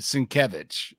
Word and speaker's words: Sienkiewicz: He writes his name phonetically in his Twitter handle. How to pronounce his name. Sienkiewicz. Yeah. – It was James Tynion. – Yeah, because people call him Sienkiewicz for like the longest time. He goes, it's Sienkiewicz.